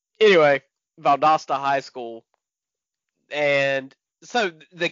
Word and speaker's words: Valdosta: Valdosta. – There it is. Valdosta.